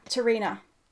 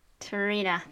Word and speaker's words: T'Rina: T- t- t- Prina, Tapina? T'Rina. T'Rina, thank you. Mm. I was like, I'm saying her name wrong.